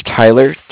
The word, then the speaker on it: Tyler: My name is Tyler, T-Y-L-E-R. – Mm-hmm. – I'm calling with Cardiff. It's spelled C-A-R-D-I-F-F as in Frank Frank.